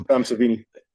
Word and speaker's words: Savini: Tom Savini.